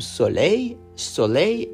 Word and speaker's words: soleil: S O L E I L. So you see, we write soleil with L at the end, but we do not pronounce it.